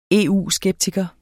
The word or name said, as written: EU-skeptiker